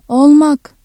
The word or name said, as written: ol-